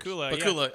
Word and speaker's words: Pakula: Pakula, I'm going to fuck that up. – Well, I'm going to fuck it up. – It's not Scott Bakula. It's Alan Pakula. – It rhymes with Dracula.